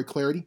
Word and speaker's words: Clardy: Hit me up on Twitter, at Troy Clarity, last name is spelled C-L-A-R-D-Y.